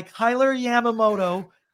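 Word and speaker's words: Kailer Yamamoto: 2 minutes later, though, after that first one, Bouchard gets out-muscled by Kyler Yamamoto. Yes, I did say that right. You heard that correctly.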